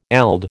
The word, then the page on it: eld: American English